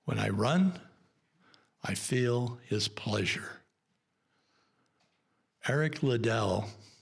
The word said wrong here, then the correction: Liddell: or I keep pronouncing his name wrong, Eric Little, because it's L-I-D-D-E-L-L. And I always want to say Liddell. It's Little. Eric Little was one of the fastest runners in the world.